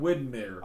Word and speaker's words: Widmeyer: He spells his last name, though. Weedmeyer. And I... – Weedmeyer. – Well, and I wasn't really sure how to say it. – Widmeyer.